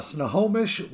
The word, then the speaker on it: Snohomish: But before we get to that, let's talk a little bit about you're from Snohomish, Washington.